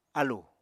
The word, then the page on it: álù: Apatani